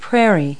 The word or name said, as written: prairie